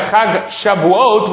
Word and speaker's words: Shavuot: Now you have to pronounce it properly. Many people pronounce it incorrectly, but in the Torah it's Chag Shavuot, which is the festival of weeks.